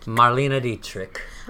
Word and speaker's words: Marlene Dietrich: So next, one of her big inspirations was, how do you say this name? Marlene Dietrich? – Marlena Dietrich.